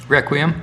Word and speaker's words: Requiem: Requiem. How do you say that? – Requiem. – Requiem. All right, I was pretty close. – You were right on.